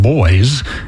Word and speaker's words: Bois: Next, we're going to hear an artist who was on the Acoustic Peace Club earlier this year, John Boys. It's spelled B-O-I-S, but pronounced like boys and girls.